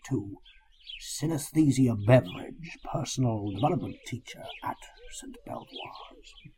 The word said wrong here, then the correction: Belvoir: Beavers. – I'm sorry? – I'm sorry, it's Beavers. – St. Beavers? Oh, right. – That's how it's pronounced.